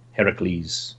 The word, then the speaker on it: Heracles: Heracles... or Hercules, both are fine pronunciations.